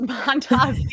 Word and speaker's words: Montas: And so obviously facing them again today, going up against Frankie Montas, who's pitching for Milwaukee against Montas. Yeah, I'm saying that right. Against Montas, the Mets are hitting as a team 275.